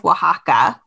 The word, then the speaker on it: Oaxaca: Um, Every year on December 23rd, the Mexican state of Oaxaca, which, by the way, I am saying correctly, um, even though it is spelled O A X A C A, mm-hmm.